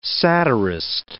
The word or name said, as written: satirist